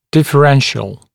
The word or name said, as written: differential